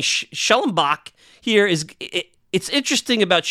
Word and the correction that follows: Schwellenbach: Am I saying his name right, by the way? Schwellenbach. You know, that's a tough name.